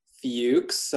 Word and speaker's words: Fuchs: Like you said, my name is Jason Fuchs. It's pronounced Fuchs. I recognize the spelling for some people can be a little difficult.